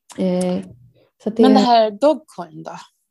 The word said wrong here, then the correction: Dogecoin: Ja, så Do Dogecoin startade... – Dogecoin. Ja, det, var... – Eh, det är i alla fall så jag uttalar, ja.